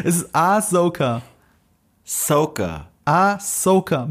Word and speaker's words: Ahsoka: Ahsoka. Ahsoka.